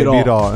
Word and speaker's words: Biro: Cioè, in realtà Biro, scusa, non okay, Biro. – Perché infatti l'accento è chiuso.